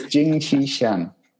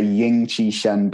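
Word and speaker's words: Jing Qi Shen: So it's Jing Chi Shen. – Jing Chi Shen. – Although some people say Ying Chi Shen, but I'm from Manchester, you know. we pronounce things differently there.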